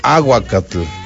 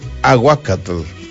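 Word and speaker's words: ahuacatl: Escuche nada más, aguacate proviene del náhuatl y se pronuncia aguacatl, aguacatl, que significa literalmente testículo, debido a la semejanza física de la fruta con esa parte del cuerpo. En náhuatl se dice aguacatl, aguacatl.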